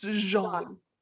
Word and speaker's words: Jean: Now, it was announced on Friday that Regé-Jean Page, am I saying that right? – Yeah, Jean, you're killing it. – Right? But is it Jean or Jean? – It's Jean.